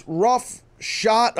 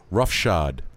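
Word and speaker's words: roughshod: It's actually, it's rough shot. How do you say it? – Rough shod. – Rough shod. Right. – With a D at the end.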